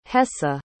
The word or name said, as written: hessa